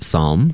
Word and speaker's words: psalm: One of the arguments for that is that the word psalm, complete with its strange silent P at the beginning, comes to English from the ancient Greek word psalmos, meaning to pluck, and the derivative word psalmoi, meaning instrumental music.